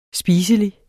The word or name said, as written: spiselig